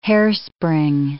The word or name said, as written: hairspring